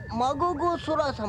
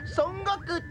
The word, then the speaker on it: 孫悟空: 歓迎孫子空様。 – あれは孫悟空って読むんじゃないですか。